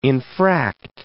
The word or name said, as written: infract